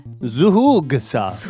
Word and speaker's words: Zhoug: Zhoug sauce.